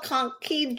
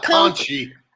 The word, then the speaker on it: Conch: And it was, I think, called Conky Joe's. How do you say that in Florida? – Conk, not conchy.